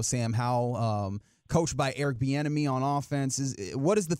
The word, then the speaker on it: Bieniemy: You said his name right there, Eric Bieniemy on the offensive side of the ball.